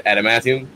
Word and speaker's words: adamantium: And I mean, that's how it is in English, right? Adamantium. Did I say it correctly?